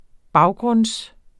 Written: baggrund

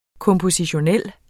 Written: kompositionel

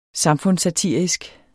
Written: samfundssatirisk

